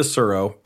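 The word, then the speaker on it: Tsuro: It's the one with the dragon on the front, and it's T-S-U-R-O. – Yeah. Pronounced like Dow. T-A-O. – Yeah. – But the... it's similar to Surro, and you're moving these pieces, and you just collect points.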